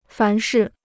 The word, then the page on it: 凡是: fán shì